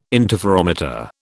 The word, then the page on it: interferometer: British English